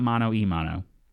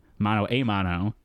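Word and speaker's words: mano a mano: Yeah, not mono e mono. Mono a mono, which is Spanish for man versus man, hand versus hand. – Oh. – Apparently us whiteys have misheard it this entire time and pronounce it mono e mono.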